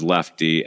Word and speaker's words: Leftee: But the connection of Big R, it's his name in the band, and mine's Lefty, L-E-F-T-E-E, instead, not the way you usually spell it, because that has to do with golf.